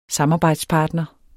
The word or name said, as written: samarbejdspartner